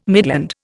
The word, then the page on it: midland: Indian